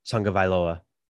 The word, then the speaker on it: Tagovailoa: No, but the pronunciation is Tagovailoa. – Tagovailoa. – Tua Tagovailoa. That's how you pronounce the name.